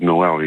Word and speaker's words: Noël: I said, but really, it's my name is pronounced as Noël.